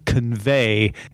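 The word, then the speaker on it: Convai: So they got together with a company called Convey, except it's spelled V-A-I.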